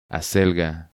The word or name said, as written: Acelga